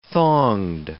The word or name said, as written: thonged